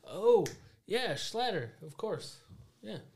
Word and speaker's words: Schlatter: How do you say that last name? You're better at pronouncing last names. – Where am I looking? – Right there. – Oh. Chalet. Schlatter. – Yeah. – Charlie Schlatter.